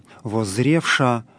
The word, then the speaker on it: воззревша: В данном случае вот это звучание буквы «малый юс» не воспроизводится. То есть в словах «воззревша», «терпяща», «глаголюща» мы видим букву «малый юс» после «шипящего», после «ша» или «ща». Но на произношение вот этого буквосочетания такое написание не влияет.